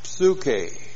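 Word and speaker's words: psuche (ψυχή): That word life is actually the Greek word psuche. P-S-U-C-H, long E.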